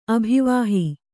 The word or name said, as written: ಅಭಿವಾಹಿ